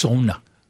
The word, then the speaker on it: sauna: It's pronounced sauna. Go to Finland, okay, and then call it sauna.